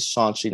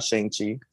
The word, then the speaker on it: Shang-Chi: Also, Eric, it's pronounced Shang-Chi. He says it in the trailer. It's Shang-Chi, not Shang-Chi.